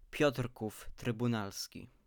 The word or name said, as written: Piotrków Trybunalski